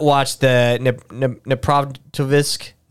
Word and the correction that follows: Dnipropetrovsk: Dneprovitovsk is where they," and I'm mispronouncing the word. It's the name of the town they're at. Dnipropetrovsk murder video.